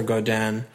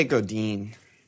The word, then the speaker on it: Godin: I mean, we plumped the extra million for Godin. – Yeah. – How do you say his name? It's not French. – I always say Godin. – Godin. I wish it was spelled Godeen.